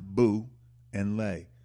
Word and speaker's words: Boule: They spell it B-O-U-L. Excuse me, B-O-U-L-E. B-O-U-L-E. Pronounced Boo and Lay. Boule.